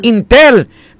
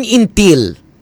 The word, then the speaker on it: Intel: Intel. Pero ari, napamangkot mong asawa kun ang pag-pronounce intel o kun intil? – Intel. – Intel. Kay kun intel, tibal-an na.